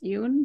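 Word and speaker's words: Yeun: And not like, so Danny Cho is played by, I think his last name is pronounced Yoon, but Steven Yoon.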